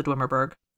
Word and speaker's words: Dwemerberg: The mountain is the Dwemerberg. I'm pronouncing that wrong. Leave me alone. – Actually, that sounds pretty good.